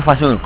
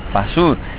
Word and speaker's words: Fasur: Pues mira, Fasur. Entonces, he encontrado un recurso que lo uso recurrentemente, y es que... Fa, nota musical, Sur, punto cardinal. Fa Sur es mi nombre.